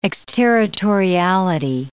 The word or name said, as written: exterritoriality